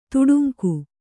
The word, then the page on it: ತೊಡಂಗ: kannada